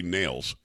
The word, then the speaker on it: Nehls: I know the last name is N-E-H-L-S, but I think he pronounces it actually Nails.